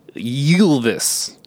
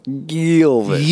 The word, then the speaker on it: Ylvis: It's pronounced Ylvis. – Ylvis.